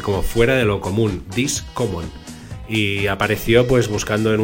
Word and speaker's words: Discommon: Ni Discomon, ni Discomun, ni Discomun. Discommon.